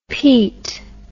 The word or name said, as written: peet